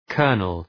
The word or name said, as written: kernel